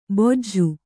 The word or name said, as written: ಬೊಜ್ಜು